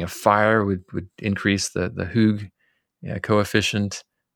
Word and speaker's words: hygge: I think it's Hygge, I think. Pronounced hygge. Anyway, it's this idea of, it's like coziness is probably the closest English translation.